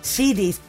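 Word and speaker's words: Sheedy: Fazendo a Patricinha, e você tem a Ellie Shiri, ou Shed. Isso, Shiri, perfeito.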